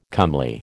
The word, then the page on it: comely: American English